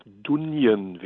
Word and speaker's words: Dungen: ich glaube, Dunjen werden sie ausgesprochen, geschrieben Dungen, zusammenarbeitet.